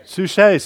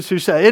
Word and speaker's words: Suchet: And David Suckett, he's an actor. I don't know, is that how you pronounce his name? Suchet, sorry. David Suchet.